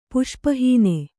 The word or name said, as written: ಪುಷ್ಪಹೀನೆ